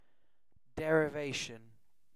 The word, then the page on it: derivation: British English